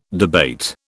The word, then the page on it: debate: British English